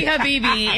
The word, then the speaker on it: Habibi: It's definitely Habibi. It's just the way it's spelled. It's Habibi. It means my love.